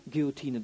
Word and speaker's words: Guillotine: Das wäre so, wie man eine Guillotine, ist das richtig ausgesprochen? Guillotine? Guillotine, danke.